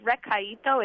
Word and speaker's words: Recaito: 2 tablespoons of Recieto Goya cilantro cooking base? What is that? – I think the way it's pronounced is Recaito. It's R-E-C-A-I-T-O.